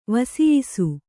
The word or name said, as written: ವಸಿಯಿಸು